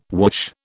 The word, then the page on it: whoosh: British English